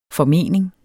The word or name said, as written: formening